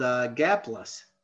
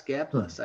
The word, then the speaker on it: Gaplus: G-A-P-L-U-S, Gapless, I assume Gapless. Is the pronunciation.